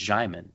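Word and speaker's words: Djimon: It's Jaimon. That's how he actually says it.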